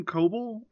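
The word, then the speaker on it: Koebel: So Adam Coble, I think that's how you say his name, right? – Yeah.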